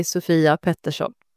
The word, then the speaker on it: Sofia Pettersson: Yeah, so it's a Swedish name, so it's pronounced. In Swedish, you say Sofia Pettersson, but it's fine.